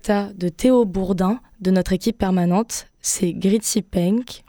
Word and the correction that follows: Green Tea Peng: Excusez-moi, mais n'importe quoi cette prononciation. Green Tea Peng, Sata de l'album Mad Made, et c'est tout récent dans vos oreilles.